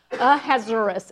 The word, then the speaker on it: Ahasuerus: Ahasuerus is how you say his name.